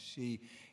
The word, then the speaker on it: Sidhe: Their band name, She, spelled S-I-D-H-E, so it's a little tricky if you want to try to Google it and find out more about them. S-I-D-H-E, pronounced She, is the duo of Michael and Keller and Millam.